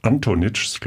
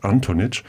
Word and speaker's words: Antonic: Es ist Nora Antonitsch, glaube ich, spricht man es aus, Antonitsch, die erst 14 Jahre alt ist und mir gegenüber sitzt.